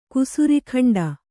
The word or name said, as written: ಕುಸುರಿಗಂಡ